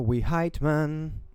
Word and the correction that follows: Whitman: Is that how you say it? Trevor Whiteman. – Just Whitman.